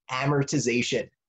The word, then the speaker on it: amortization: it's called amortization.